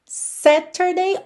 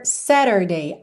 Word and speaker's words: Saturday: Aí aqui, como a gente tem um tzinho no meio da palavra, se você optar por uma pronúncia mais americana, você vai fazer o rarara nesse T e vai ter então Saturday, Saturday. Se você optar por uma pronúncia mais britânica, Saturday, Saturday.